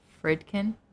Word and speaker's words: Friedkin: Friedkin. – Oh, I don't, It's spelled like fried kin. – Like fried kin or like fried can? – Kin. K-I-N.